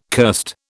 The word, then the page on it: cursed: British English